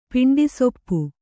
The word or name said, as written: ಪಿಂಡಿಸೊಪ್ಪು